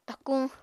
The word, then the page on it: takuñ: apatani